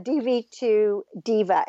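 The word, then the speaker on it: Devi 2 Diva: So is this what was your first impetus to write the DV to Diva? And it's spelled, for those of you who are listening, D-E-V-I, the number 2, D-I-V-A.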